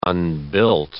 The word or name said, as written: unbuilt